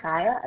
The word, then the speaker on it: Chaya: C-H-A-Y-A, Chaya, initial G. – C-H-A-I, you said A? – A-Y-A, Chaya, and my initial is G.